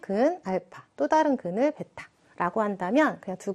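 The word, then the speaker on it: 베타라고 (β): 알파, 베타라고 읽어주시면 되고, 우리 방정식에서의 근을 표현하는 그리스 소문자다라고 그냥 생각하시면 됩니다.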